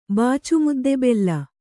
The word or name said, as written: ಬಾಚುಮುದ್ದೆಬೆಲ್ಲ